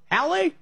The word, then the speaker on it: Halle: But how do you say that other city? You got Berlin and Hal? Hale? – Halle? Yeah.